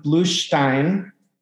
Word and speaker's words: Bluestein: I am here today with Jonathan Bluestein.